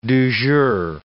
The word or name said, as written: du jour